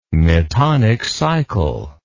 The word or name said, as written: metonic cycle